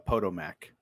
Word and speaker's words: Potomac: Andy, I believe that's pronounced Potomac.